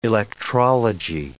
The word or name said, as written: electrology